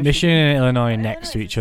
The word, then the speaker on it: Illinois: And on the 24th, a small group of people gathered outside Dorothy's house, which is in Illinois, that's how you say it. – Illinois.